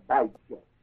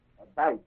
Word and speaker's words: Abijah: Now under Judah, across from Jeroboam, the king reigned in Judah along with Jeroboam in Israel, was a king named Abijah, A-B-I-G-J-A-H, Abijah. Is it J or G? J-A-H. Long I. Abijah. That's good. And Ahab, the king for Judah was Jehoshaphat. J-E-H-O-S-H-O, I guess.